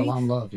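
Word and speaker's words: Salaam Love: I also know that you wrote an opening piece called Growing Up Muslim in the anthology Salam Love. Am I pronouncing that correctly? – Salam Love.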